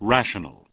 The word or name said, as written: Rational